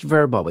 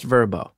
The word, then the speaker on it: Vrbo: She said, It's not pronounced Verbo, it's V R B O. I said, Mom, they're an advertiser on my show. It's pronounced Verbo.